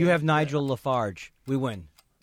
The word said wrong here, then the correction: Nigel Farage: That's not how you say his name. – Wait, how do you say his name? – All right, your time is up. Your time is up. What is it? – It's Nigel Farage.